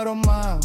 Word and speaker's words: рома: Ни цыгане, а рома, ударение на а.